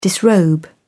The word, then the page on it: disrobe: British English